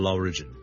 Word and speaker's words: origin: Number two 呢，如果讀成 origin 呢就會怪怪地啦，因為個重音其實 origin O-R-I-G-I-N 個來源呢，我哋個重音係第一個音節嘅，所以 single law origin origin。第三件事要留意，如果讀成 origin 呢，除咗我將個重音放咗喺第二個音節之外呢，我哋個 vowel 都唔同咗，我哋唔係 region。譬如我 R-E-G-I-O-N 一個地區，我哋叫 region 呢，咁呢就係 re 我哋嘅 e vowel。